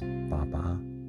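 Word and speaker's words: Baba (八八): Taiwan celebrates it a little bit differently because 8-8 in Chinese is pronounced Baba, which obviously is father.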